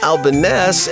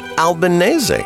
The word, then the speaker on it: Albanese: Some don't know why that second E is there and say Albanese. And some, like Vinny here, say Albanese. Maybe with an Italian accent.